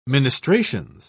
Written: ministrations

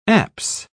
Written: apse

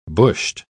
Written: bushed

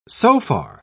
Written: sofar

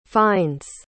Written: finds

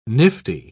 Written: nifty

